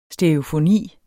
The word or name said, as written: stereofoni